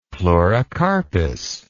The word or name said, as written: pleurocarpous